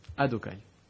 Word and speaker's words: Adokai: Oui, on peut le prononcer. C'est le prononcer Be'adunut, Adokai.